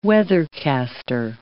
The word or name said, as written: weathercaster